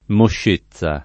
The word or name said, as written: moscezza